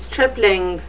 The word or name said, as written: stripling